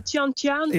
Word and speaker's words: Chianciano: Ja, Chianciano.